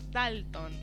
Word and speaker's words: Dalton: Porque cuando no tiene tilde en la última sílaba, se acentúa en la primera. Entonces es Dalton.